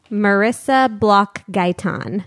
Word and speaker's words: Marisa Bloch Gaytan: Say your name out loud so I get the pronunciation right. – Marisa Bloch Gaytan.